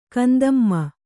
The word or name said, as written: ಕಂದಮ್ಮ